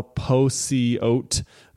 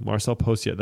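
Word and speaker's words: Marcel Pociot: He said it on Laracon Online and I literally wrote down the pronunciation like in my in my notebook. I was like, Posiote. Marcel Posiote. That's how he says it himself.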